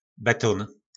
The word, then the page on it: Betón: Po našimu